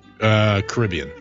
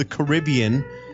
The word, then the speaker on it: Caribbean: How do you say this word? C-A-R-I-B-B-E-A-N. – Caribbean. – Yeah, you do. Oh, I thought it was Caribbean. Caribbean. – Yeah. – I always mix them up. I never know which one because I've heard both my entire life, and I heard somebody doing it earlier, and they used it wrong.